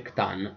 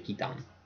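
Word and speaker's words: C'tan: Perché ricordiamo sempre che non si dice Khtan, ma si dice Khitan.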